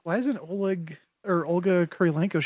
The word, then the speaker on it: Kurylenko: Kurylenko, yes, thank you.